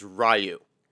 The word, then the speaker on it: Ryu: Elsewhere in Shadaloo City, con men, Ken and Ryu, we have to be perfectly clear, his name is Ryu. – Yes. – Pronounce it right.